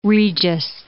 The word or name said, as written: Lynn Regis